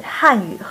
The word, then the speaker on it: 汉语: 看来啊，不仅汉语和韩语这两个词的发音很像，这两种语言其实也真的有相似的地方。 – 是啊，我刚学汉语的时候就觉得很有趣。汉语的“ 汉” 是四声，韩语的“ 韩” 是二声，这两个词的发音很像。